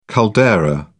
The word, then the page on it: caldera: British English